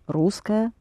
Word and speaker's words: русская: Это интересная русская традиция. Да. Uh-huh. And how do you say she is Russian? – Она русская. Русская. – Русская. U is accented.